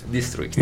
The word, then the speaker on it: District: Dixtris. – District. – Vedi District. come lo dice bene. Par, farlo pronunciare che Pro- mm, l'ha pronunciato bene.